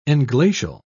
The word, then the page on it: englacial: British English